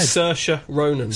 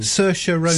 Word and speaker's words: Saoirse Ronan: Saoirse Ronan. – Saoirse Ronan. – Saoirse. – It's spelled S-I-S-A-O-I, whatever, Irish.